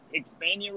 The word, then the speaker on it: Hispania: is the Hispania race. Did I say that correctly? – Sounds good to me.